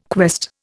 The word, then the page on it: quest: Indian